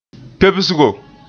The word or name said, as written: pebsgo